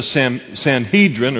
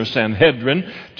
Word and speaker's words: Sanhedrin: To be a member of the Sanhedrin, or Sanhedrin, two different pronunciations, was to be one of 70 in the entire world.